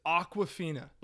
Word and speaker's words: Awkwafina: It's Awkwafina. – Like the water? – But it's spelled A-W-K, I think W-A-F-I-N-A.